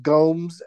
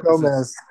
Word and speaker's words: Gomez: Wait, so is it pronounced Gomes Adams? – Well, it's a Gomez. It's a Z. – It's a Z, so it's Gomez.